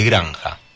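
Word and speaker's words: Granxa: Granja Familiar es granja así con X, ¿no? Se pronuncia granja porque es gallego.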